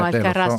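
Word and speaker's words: Alcarràs: Ho sbagliato l'accento. – No, fa niente. – No, è gravissimo. Alcarras, ce l'ho anche scritto davanti.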